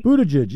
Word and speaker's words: Buttigieg: Peter Buttigieg, did I pronounce that right this time, by the way? – Buttigieg, yep.